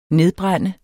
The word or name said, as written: nedbrænde